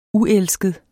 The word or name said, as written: uelsket